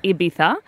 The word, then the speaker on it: Ibiza: Ibiza.